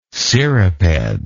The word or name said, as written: cirriped